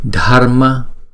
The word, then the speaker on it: Dharma: Dharma, chúng ta đọc và phải nghe cái âm R trong đó ha.